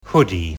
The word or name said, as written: hoodie